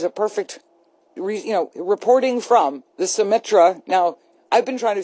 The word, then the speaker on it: Symetra: Symetra.